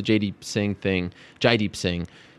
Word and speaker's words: Jaideep Singh: In short, I reported the Jaideep Singh is the correct way to pronounce his name.